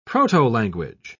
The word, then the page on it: protolanguage: British English